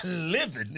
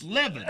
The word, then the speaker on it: Levin: Levin is Levin.